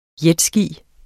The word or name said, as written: jetski